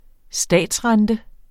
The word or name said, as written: statsrente